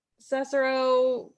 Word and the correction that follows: Cesaro: What, how do you say his name? – Cesaro. – My apologies, Cesaro.